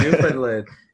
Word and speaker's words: Newfoundland: Adam's all the way in Newfoundland right now. So it is good job on the pronunciation. Good job there. – Yeah, Newfoundland.